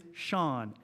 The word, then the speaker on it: Sean: Okay, my name is Sean. S-E-A-N.